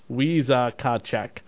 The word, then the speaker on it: Wisakachek: In this legend, there is a spirit called Wisakachek, Native American mythology, pronounced Weeza Kachek, was a shapeshifter who lived in the woods.